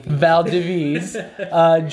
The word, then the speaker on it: Valdiviez: We had Joel Valdiviez, if I say your name right. – Valdiviez, joining us again.